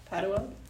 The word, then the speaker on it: Padua: My name is Celeste Padua. – Padua? – Yeah. – And how do you spell it? – C-E-L-E-S-T-E-P-A-D-U-A.